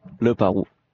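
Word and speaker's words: Leparoux: We're roommates here in Saratoga, and he told me that I pronounce Leperoux incorrectly. – Yeah. – He said the proper pronunciation is Leperoux. – Leperoux.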